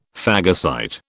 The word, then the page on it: phagocyte: British English